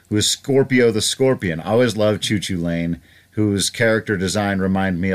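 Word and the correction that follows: Cú Chulainn: If I could interrupt on a point of pronunciation, that is an old Irish god, and I'm pretty sure it's pronounced Cuchulain. – Cuchulain.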